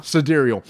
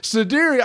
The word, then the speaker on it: Sidereal: Sidereal. – Sidereal.